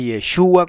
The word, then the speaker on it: Yeshua: Es Yeshua con acento en la U, es Yahweh con acento en la E.